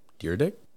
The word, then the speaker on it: Dyrdek: Because I saw Rob Dyrdek. – Yep. – Dyrdek? – No, you said it right the first time.